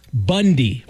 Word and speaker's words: Bounde: The problem is, though, their last name is B-O-U-N-D-E. And that's pronounced Bundy.